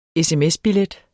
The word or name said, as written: sms-billet